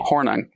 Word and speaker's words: Hornung: Hornung.